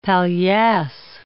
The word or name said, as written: palliasse